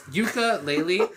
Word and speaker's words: Yooka-Laylee: Yeah, so it's Yooka-Laylee, and they're a team duo again.